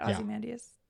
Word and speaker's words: Ozymandias: Ozymandias? – Yeah.